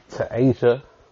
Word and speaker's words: Tasia: I think it's Tasia. It's T-A-S-I-A. – You got it right, bro.